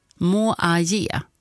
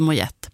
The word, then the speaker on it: Moyet: Tack så mycket Stina då för att du skrev in att uttalet ska vara som i Allison Mojaje. Mojaje. Ja, Mojaje. Inte Mojette.